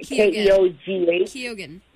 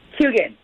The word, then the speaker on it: Keoghan: And don't judge me on those, but it's Barry Keoghan. I don't know how to say Keoghan. – But how do you say it? – Keoghan.